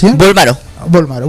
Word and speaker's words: Bolmaro: Eh, muy bien, eh, Bolmarelio. Hasta, ¿Lo dije bien, Bolmaro.